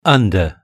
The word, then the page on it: under: British English